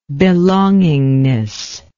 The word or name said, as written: belongingness